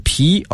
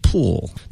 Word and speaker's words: pool: 词汇畅谈，这个单词的拼写是 pool， pool。当然这个发音一定要把那 l 带出来，不然的话就变另外一个意思了。